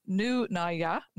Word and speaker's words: Nunaia: It's very hard to pronounce, but it's apparently pronounced Nunaia, spelled Nunaia.